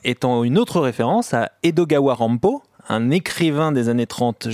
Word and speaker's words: Edogawa Rampo: Edogawa Rampo. Quand on prononce Edgar Allan Poe en japonais, ça fait Edogawa Rampo.